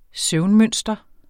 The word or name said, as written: søvnmønster